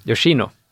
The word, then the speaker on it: Yoshino: Uh, den heter Pojken och hägen, tydligen baserad på en bok av uh, vi ska se, Gensaburo Yoshini. – Mm. Yoshino.